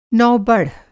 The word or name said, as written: नौबढ़